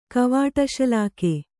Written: ಕವಾಟಶಲಾಕೆ

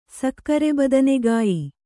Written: ಸಕ್ಕರೆಬದನೆಗಾಯಿ

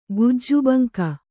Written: ಗುಜ್ಜುಬಂಕ

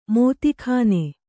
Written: ಮೋದೀಖಾನೆ

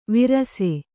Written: ವಿರಸೆ

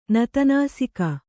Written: ನತನಾಸಿಕ

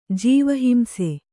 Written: ಜೀವಹಿಂಸೆ

